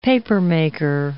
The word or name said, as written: papermaker